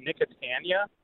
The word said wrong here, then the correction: Nicotiana: And it has these beautiful white trumpet flowers on it. And I'm curious how big this plant gets because it's just becoming massive. – Yeah. So in my neck of the woods, we pronounce it Nicotiana.